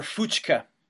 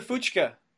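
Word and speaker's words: Fučka: And his name was Gregor Fuchka, but it's spelled F-U-C-K-A.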